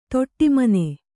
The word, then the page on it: ದುಢುಮ್ಮನೆ: duḍhummane